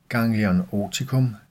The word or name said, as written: ggl. oticum